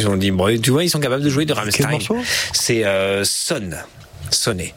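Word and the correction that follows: Sonne: Ah, zone. – Ouais. Oui, je l'ai prononcé comme il était écrit.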